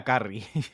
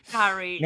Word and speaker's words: Curry: Sí, el apellido es Curry como el pollo al culo. Entonces se pronuncia Carrie. – Carrie.